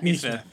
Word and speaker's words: Nesmith: Neesmith. I mean, it's spelled like Nesmith, so I understand. – I've been saying in my defense, I've been saying Nesmith for so long in my head that like I've heard you say it a couple times, and I was like, "Fuck."